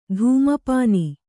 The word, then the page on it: ಧೂಮಪಾನ: kannada; dhūma pāna